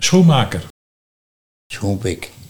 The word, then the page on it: Schoenpik: Nieuw lekkerlands